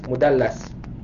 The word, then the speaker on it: mudallas: Al-mudallas ini cara bacanya adalah dengan lam yang ditasydid, mudallas. Ya, ini isim maf'ul. Kalau mudallis itu orangnya, isim fa'il.